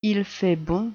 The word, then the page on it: il fait bon: French